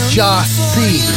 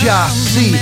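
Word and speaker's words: Gyasi: Pronounced Ja-C. It's Ja-C. G-Y-A-S-I.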